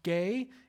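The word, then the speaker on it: gay (γῆ): The word earth, it's pronounced gay in the Greek.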